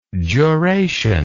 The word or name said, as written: juration